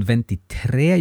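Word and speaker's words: ventitré: And then on ventitre, you get an accented e at the end. So the, the stress of the pronunciation becomes tre, And vent otto again is instead of venti otto, you get vent otto.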